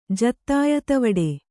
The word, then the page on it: ಜತ್ತಾಯತವಡೆ: Kannada